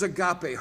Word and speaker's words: agape: The fourth is agape, hard G again.